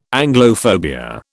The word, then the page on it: Anglophobia: British English